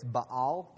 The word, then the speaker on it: Baal: When they eventually became worshipers of Baal, B-A-A-L. If you want to pronounce it super straight, it's Baal, but we're in the South, so I say Baal.